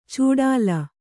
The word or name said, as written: ಚೂಡಾಲ